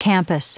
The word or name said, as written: campus